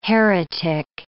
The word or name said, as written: heretic